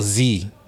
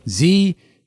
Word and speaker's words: Xi: What's the pronunciation for that? – XI. Oh, Z. – Z.